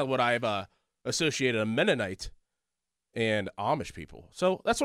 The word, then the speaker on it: Mennonite: There's a Mennonite? Did I say that correctly? Mennonite? – Sure.